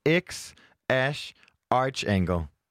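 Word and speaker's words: X Æ A-Xii: at det udtales X Ash Archangle.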